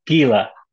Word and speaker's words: Gila: From the top of the outer wall, Knight Gaia rained down. Gaia? How do you say that one? Just tell me how. – Gila. – Yeah. – Okay. Gila, like a Gila monster.